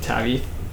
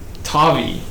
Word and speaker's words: Tavi: Tavi. Like toffee, like the candy, but like. – With a V.